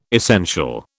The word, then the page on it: essential: British English